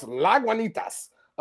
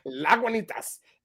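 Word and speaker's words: Lagunitas: It's pronounced La Guanitas Brewing Company. – No, it's not. – It's La Guanitas. And it is a brewing company.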